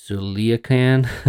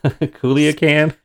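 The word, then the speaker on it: Culiacán: No, please try to pronounce it. – Culiacan. Culiacan. – Spot on.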